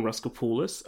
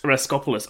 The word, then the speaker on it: Raskopoulos: Well, today I'm talking with Jordan Raskopoulos. Am I pronouncing that correctly? – No, that's incorrect. It's Raskopoulos. – Raskopoulos.